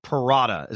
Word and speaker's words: Parada: But when they drafted Parada, is that how you pronounce it, by the way, Kevin Parada, the catcher they drafted? – Yep. All right. Yep.